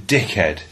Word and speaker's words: dickhead: And you don't really pronounce the H. You just go, you fucking dickhead.